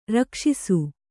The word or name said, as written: ರಕ್ಷಸ್ಸು